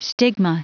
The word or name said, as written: stigma